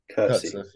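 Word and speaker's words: Coetzee: Uh, uh, Kurtz, Kurtze, Kurtze, how do you pronounce it? – Kurtze. yeah. – Kurtze, let's go Kurtze.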